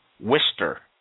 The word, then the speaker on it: Worcester: I was told that the people up north call it Wister, W-I-S-T-E-R, or it's pronounced Wister, W-I-S-T-E-R, Wister.